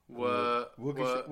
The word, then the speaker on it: Waukesha: E-S-H-A. – How many E's? – W-A-U-K. There's one E. – S-H-A. – S-H-A. – How do you pronounce it? – Wakisha? – That's probably what I would have went with. – Waukesha. – Waukesha? – Waukesha.